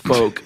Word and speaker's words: folk: In fact, up until about a year ago when someone wrote in and said, you stupid, it's pronounced folk, like F-O-K-E. – Folk. – And not folk.